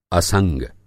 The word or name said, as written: असंग